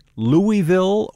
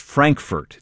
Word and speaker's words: Frankfort: Is the capital of Kentucky pronounced Louisville or Louisville? Well, the correct answer is that it's pronounced Frankfurt.